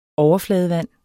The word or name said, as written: overfladevand